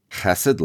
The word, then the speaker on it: chesed: It rhymes with the two-syllable version of blessed. In Hebrew, the first letter makes a throaty sound like the German ch in Bach, but you can just say chesed in English.